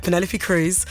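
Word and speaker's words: Penélope Cruz: So it's Penelope Cruz. You have to say it like that. Penelope Cruz.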